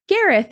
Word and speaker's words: Gareth: This um, is Gareth, and I hope I pronounced that correctly.